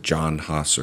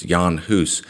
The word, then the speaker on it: Jan Hus: Sometimes people pronounce it John Huss or Jan Hus. It's Jan Hus.